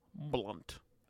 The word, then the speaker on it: Blount: William Blunt. Well, his last name, it looks like Blount. – Oh. – But it looks like, but it's pronounced Blunt.